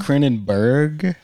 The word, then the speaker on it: Cronenberg: That's pronounced Cronenberg.